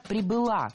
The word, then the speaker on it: прибыла: Когда совершенно безумно ее мама прибыла в Голливуд, зачем-то где-то устроилась какой-то монтажницей, вышла, была замужем, родила дочку. – Прибыла, неверно, правильно, прибыла. Ударение фиксируем все там же, на окончании.